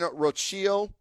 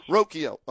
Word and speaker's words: Rocchio: But Daniel Espino, Gavin Williams, George Valera, Bo Naylor, Tanner Beebe, Brian Rocchio. I don't know if I pronounced that right. – Rocchio. And Chase, you're close. – Rocchio. Okay.